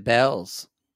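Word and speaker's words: Bealls: Like B-E-L-L apostrophe S or like Bell, Southern Bells? – B-E-A-L-L apostrophe S. – Beals. – But we know we pronounce it Bells.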